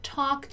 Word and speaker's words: talk: Also, attention here to the pronunciation of the word talk, right? Talk. It's something like talk, something like that. A long O.